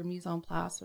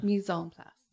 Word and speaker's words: mise en place: And you need to have your mise en place, which is like all your chopped stuff ready to go for your pan. – Sorry, how do you pronounce that? – Mise en place.